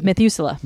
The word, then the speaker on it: Methuselah: And then there's the six liter, which people call imperial or or mezzal. – How do you say it? Methuselah.